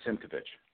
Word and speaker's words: Simkovich: Simkovich.